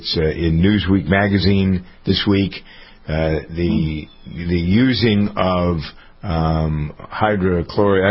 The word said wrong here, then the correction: hydroxychloroquine: I never pronounce it right, the combination of hydroxychloroquine.